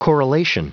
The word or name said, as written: correlation